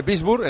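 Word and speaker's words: Würzburg: Y hay descanso también en la Euroliga femenina en Wurzburg, que se escribe Wurzburg, pero se dice Wurzburg, en el pabellón del Perfumerías Avenida de Salamanca.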